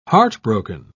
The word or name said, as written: heartbroken